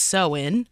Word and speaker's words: Samhain: So with Halloween in particular, you have sort of this ancient Celtic tradition of Samhain, which is not does not sound the way that it's spelled, but I learned today that it's sewin.